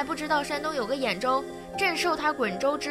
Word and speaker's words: 兖州: 他方知道兖州不是滚州，这滚字的读音是滚而不是兖，但是要皇帝认错不是那么容易的事儿啊。